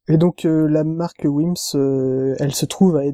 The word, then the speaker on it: Wemyss: Et cette petite perle est concoctée par la famille Wims, enfin, ou wimis. C'est écrit, enfin, ça s'écrit W-E-M-Y-S-S.